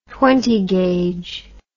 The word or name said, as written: twentyfourth amendent